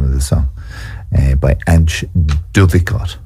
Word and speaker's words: Antje: Yeah, Antje. A-N-T-J-E.